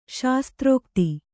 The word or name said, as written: ಶಾಸ್ತ್ರೋಕ್ತಿ